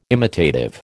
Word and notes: imitative: American English

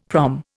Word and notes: prom: Indian

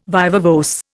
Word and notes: viva voce: Indian

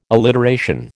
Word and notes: alliteration: American English

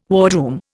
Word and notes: wardroom: Indian

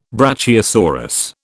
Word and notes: brachiosaurus: British English

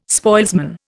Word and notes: spoilsman: Indian

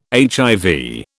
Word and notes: HIV: British English

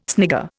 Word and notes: snigger: Indian

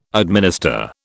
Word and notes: administer: British English

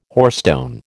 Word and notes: hoarstone: American English

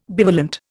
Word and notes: bivalent: Indian

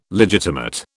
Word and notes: legitimate: British English

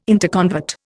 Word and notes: interconvert: Indian

